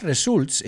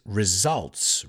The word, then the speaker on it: results: It's not results, it's results.